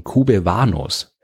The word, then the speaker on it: Kubewanos: So typisch, dass er zum Namensgeber einer ganzen Gruppe von Asteroiden geworden ist, den Kubewanos. Diese Bezeichnung leitet sich von QB1 ab, das englisch ausgesprochen wie QB1 klingt.